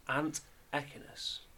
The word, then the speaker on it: antechinus: Ant echinus. – A-N-T-E-C-H-I-N-U-S. – Ant echinus. Yeah, that sounds about right.